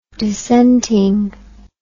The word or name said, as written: dissentingly